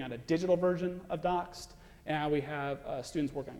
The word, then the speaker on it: D0X3D: So this is the website, D0X3D, pronounced Doxed. Let me show you, so it's all available on GitHub.